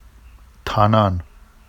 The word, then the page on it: tɑnɑn: Vafsi